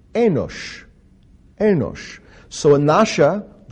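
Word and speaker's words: enosha (אנשא): And the Aramaic term here translated men is enosha, enosha. And it's spelled Aleph from right to left, Aleph, Nun, Shin, Aleph, enosha.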